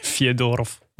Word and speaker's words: Fyodorov: Moet, ja, daar ligt de klemtoon. – Ja, oké. – Okay. Maar is met een J ook. Fyodorov. – Ja, de E in het Russisch is een J. Fyodorov.